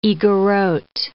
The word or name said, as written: Igorot